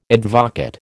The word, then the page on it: advocaat: American English